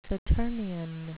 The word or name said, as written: saturnian